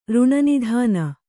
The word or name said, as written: ಋಣನಿಧಾನ